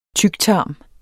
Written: tyktarm

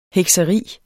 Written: hekseri

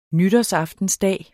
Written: nytårsaftensdag

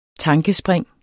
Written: tankespring

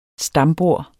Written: stambord

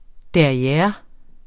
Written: derriere

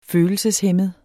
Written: følelseshæmmet

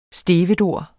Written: stevedore

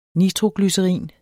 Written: nitroglycerin

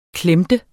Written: klemte

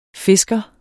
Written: fisker

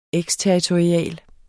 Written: eksterritorial

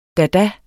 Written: dada

